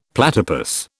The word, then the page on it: platypus: British English